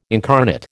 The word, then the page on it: incarnate: American English